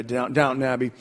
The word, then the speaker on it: Downton Abbey: So you can think Downton Abbey or Downton Abbey. I didn't say that right, did I? Downton Abbey.